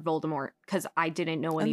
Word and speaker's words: Voldemort: It was supposed to be pronounced Voldemort. – Yeah, it was supposed to be a hard T.